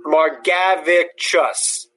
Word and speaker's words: Margevicius: But like Nick Margevicius, I don't know how to pronounce his name, the lefty for the Padres now in Seattle. – Nick Margevicius, I thought it was. – Margavicus. – Margavicus. – Margavicus. That's what it says here.